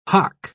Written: hough

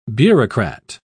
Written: bureaucrat

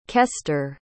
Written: kester

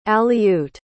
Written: aleut

